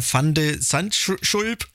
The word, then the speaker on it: Van de Zandschulp: Und hat er gegen den Niederländer Van de Zandschulp hast du sehr schön ausgesprochen.